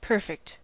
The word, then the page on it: perfect: American English